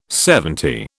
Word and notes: seventy: British English

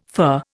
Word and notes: fur: Indian

